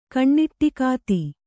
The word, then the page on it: ಕಣ್ಣಿಟ್ಟಿಕಾತಿ: Kannada